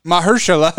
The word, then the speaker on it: Mahershala: I always forget how to pronounce his name. – Mahershala? How many syllables in that? – Mahershala. – Mahershala.